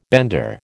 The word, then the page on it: bender: American English